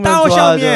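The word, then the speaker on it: 따오샤오미 (刀削面): 아, 도삭면. 따오샤오미. – 네.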